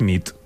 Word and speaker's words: nit: Y noche es nit. – Muy bien. – Bona nit. – Bona nit, es verdad. – Bona nit.